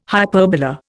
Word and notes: hyperbola: Indian

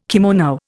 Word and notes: kimono: Indian

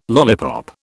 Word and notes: lollipop: British English

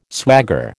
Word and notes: swagger: American English

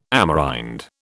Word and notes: Amerind: British English

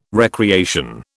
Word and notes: recreation: British English

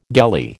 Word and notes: gelly: American English

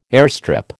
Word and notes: airstrip: American English

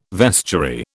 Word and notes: vestiary: British English

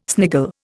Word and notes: sniggle: Indian

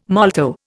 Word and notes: molto: Indian

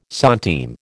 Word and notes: centime: American English

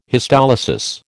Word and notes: histolysis: American English

